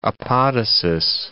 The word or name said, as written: apodosis